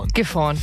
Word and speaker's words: Gifhorn: Heute geht's für Lars mit dem Tandem von Gifhorn nach Hannover. Habe ich es richtig ausgesprochen? Gifhorn, ja.